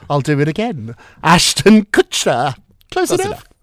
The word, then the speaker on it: Kutcher: Did I not say his last name right? How do you say it? – Kutcher. – Kutcher? Kutcher. What did I say? – Kushner? That Kushner, that's the Kutcher.